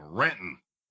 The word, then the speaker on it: Renton: Pat, you're saying it wrong. Renton.